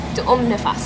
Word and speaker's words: tuum nifas: Is it tuum nifas? – It's tuum nifas.